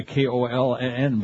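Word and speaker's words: Köln: C-O-L-O-G-N-E, cologne. Or you could spell it the German way, K-O-L-N, but I don't think we have the thing with the two dots on our typewriter.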